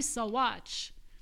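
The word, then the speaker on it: Saguache: Well, and that's how you pronounce it, everybody. Sawatch.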